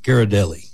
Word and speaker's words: Ghirardelli: Ghirardelli.